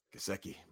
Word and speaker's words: Gesicki: But to me, it's Mike Gesicki. I'm franchising him. – You always throw that soft G at me, and it really throws me off. – Gesicki, my bad.